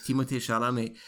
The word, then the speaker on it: Chalamet: Chalamet, probably. – That's it, Timothée Chalamet.